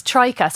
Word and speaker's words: Tryka: It's Trika. So T-R-Y-K-A.